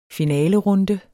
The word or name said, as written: finalerunde